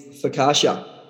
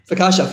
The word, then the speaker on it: focaccia: And so our first one is focaccia. Am I even saying that correctly? – Yep. – Focaccia, first thing that comes to mind.